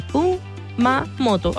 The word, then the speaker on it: Kumamoto: Kumamoto.